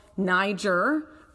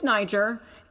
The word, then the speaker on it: Nyjer: and they decided to stick with Niger and respell it. So they now spell it N-Y-J-E-R. – Oh, that's very interesting. – Yes, and that actually leads you to say it more in the proper way that you want to say it.